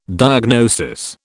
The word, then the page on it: diagnosis: British English